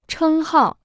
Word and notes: 称号: chēng hào

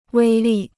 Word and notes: 威力: wēi lì

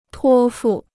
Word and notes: 托付: tuō fù

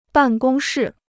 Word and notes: 办公室: bàn gōng shì

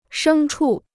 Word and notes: 牲畜: shēng chù

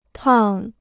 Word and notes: 趟: tàng

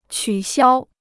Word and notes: 取消: qǔ xiāo

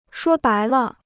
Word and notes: 说白了: shuō bái le

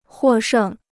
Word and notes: 获胜: huò shèng

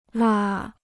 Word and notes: 蜡: là

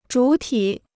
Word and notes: 主体: zhǔ tǐ

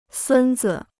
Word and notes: 孙子: sūn zi

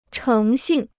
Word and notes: 诚信: chéng xìn

